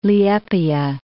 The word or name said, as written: Liepaja